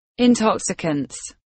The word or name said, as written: intoxicants